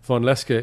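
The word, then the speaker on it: Vonlenska: But Vonleska is how to say it in Iceland.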